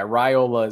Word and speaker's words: Raiola: Is that what we learned is the pronunciation? Raiola? – Yeah, I think that's the pronunciation I've heard. So that's what I'm going with. I've said Raiola in my mind a bunch of times, but yeah, Raiola is what I was told, I saw on a message board somewhere, almost like a PSA, like, hey, just so everybody knows, it's Raiola.